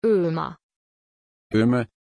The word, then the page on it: ömer: German